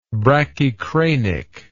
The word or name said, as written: brachycranic